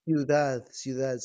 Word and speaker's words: Ciudad: So, no, I think you have the one that's called El Cuidado, if I'm saying that correctly. Oh, see, my Spanish is horrible. – Ciudad, City of Drama, Ciudad del Drama. – Oh, see, you said it so nicely.